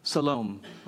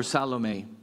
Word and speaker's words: Salome: Her name is Salome, or Salome. People pronounce it different ways.